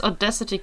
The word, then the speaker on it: Audacity: Ich habe es als Audacity kennengelernt. – Okay. – Es gibt ja auch das englische Wort Audacity.